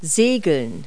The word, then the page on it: sail: verb